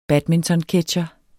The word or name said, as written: badmintonketsjer